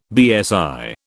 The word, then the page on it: BSI: British English